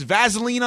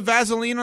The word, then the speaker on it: Vaseline: You use it, you pronounce it with a Z? – Yeah, Vaseline. – Vaseline? – I didn't know. Wait, again, how did you just pronounce it? I want to make sure I understand this. – Vaseline. – Vaseline. You know what? She's right, because there's no Z in it.